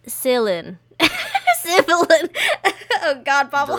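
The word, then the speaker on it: Svyelun: I would say Svielen. – Oh. – Svielen. Like, it looks a little Norsey.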